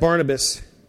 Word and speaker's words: Barnabas: And the news came in verse 22 to the ears of the church of Jerusalem, and they sent Barnabas.